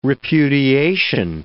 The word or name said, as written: repudiation